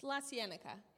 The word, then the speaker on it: La Cienega: It's on Cahuenga. – What? – Chattanooga? – It's La Cienega.